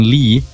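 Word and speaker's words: Lie: And then Lee, and you pronounce that as it is L-E-E. So often when I'm talking to people outside of Norway, they believe I'm Asian.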